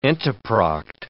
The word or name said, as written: entoproct